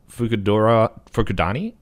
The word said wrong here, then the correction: Fukurodani: I think it's Fukurodani.